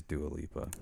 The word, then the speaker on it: Dua Lipa: Now, album of the year, I mean, I want to maybe say Taylor Swift, but maybe Dua Lipa. Did I say that right? – Dua Lipa.